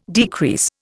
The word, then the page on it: decrease: Indian